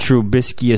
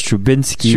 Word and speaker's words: Trubisky: Yeah, yes, and he pronounced Trubisky as Trubinsky.